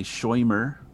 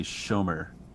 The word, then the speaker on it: Shomer: I want to make sure I pronounced it right. Shermer. – Yes. And there's a couple pronunciations in the film. You know, in the community, they say Shoimer. And then kind of in outside the community, a lot of folks say Shomer or Shomer, that sort of thing.